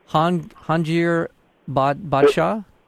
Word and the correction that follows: Jahangir Badshah: It's Jahangir Badshah.